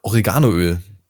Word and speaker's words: Oregano: Oreganoöl. Oder ja, nein, mega. Entschuldigung, ich habe es schon wieder falsch ausgesprochen. Das heißt ja eigentlich Oregano.